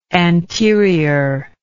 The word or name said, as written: anteriorly